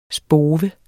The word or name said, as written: spove